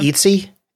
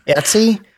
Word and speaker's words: Etsy: Etsy, which a lot of people are familiar with, that they're online shoppers, is in the consumer discretionary sector.